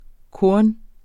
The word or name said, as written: KORNENES